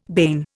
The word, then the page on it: bane: Indian